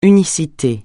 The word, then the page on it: unicité: francais